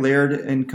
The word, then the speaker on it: Laird: He is with Laird. Am I saying that right? Laird & Company? – That's right. Laird & Company.